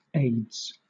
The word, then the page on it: aides: British English